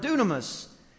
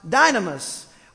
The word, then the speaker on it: dunamis: It's actually the word dunamis, which is sometimes pronounced dynamis,